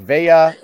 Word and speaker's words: Veilleux: His last name is spelled V-E-I-L-L-E-U-X. How would you pronounce that name? – V-E-L-L. Is it like Velia? – That's what I said. I was like, it's French. I was like, Veya is what I would go with.